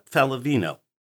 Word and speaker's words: Faliveno: Today we are talking with Melissa Faliveno. I pronounced that right? – Perfect.